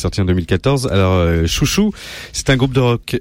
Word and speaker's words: Xiu Xiu: Euh, juste avant, on écoutait donc Xiu Xiu, qu'on prononce Chouchou.